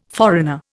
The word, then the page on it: foreigner: Indian